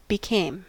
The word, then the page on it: became: American English